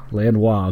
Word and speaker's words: Lanois: Lanois, okay. – Yeah. Produce. I only know that because there's a video where Bono introduces him as Daniel Lanois.